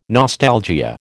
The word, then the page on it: nostalgia: American English